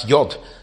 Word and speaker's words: yod: It would be pronounced perhaps yod today, and it's just a bit like a comma or an apostrophe in a word.